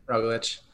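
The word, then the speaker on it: Roglič: Roglic.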